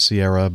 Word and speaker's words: Ciara: I'm sure you've seen some of the YouTube clips of the recent crosswind landings when Storm Ciara, or some people pronounce it Sierra, but I think it's an Irish name, and I know somebody who named their daughter Ciara, and that's at least the way they pronounced it, passed through Northern Europe on Sunday, the 9th of January.